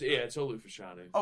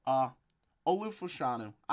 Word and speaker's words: Olu Fashanu: I hope I'm pronouncing that right. I'm definitely not. – Yeah, it's Olu Fashanu. – Okay, cool. Olu Fashanu. I just didn't know if I was remembering the last name right.